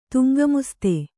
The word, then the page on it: ದಂಗಾಮಸ್ತಿ: kannada